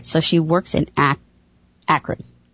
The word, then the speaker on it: Akron: It's Akron.